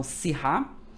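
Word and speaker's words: Syrah: Escrita si e se lê, tá? Se pronuncia si ok? O H aqui como se fosse mudo e, um acento agudo no A, então Sirra, que é a grafia mais utilizada, que é a grafia francesa.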